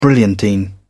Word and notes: brilliantine: British English

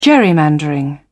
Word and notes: gerrymandering: British English

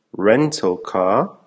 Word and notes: rental car: American English

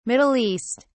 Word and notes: Middle East: British English

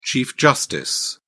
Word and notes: chief justice: British English

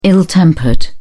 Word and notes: ill tempered: British English

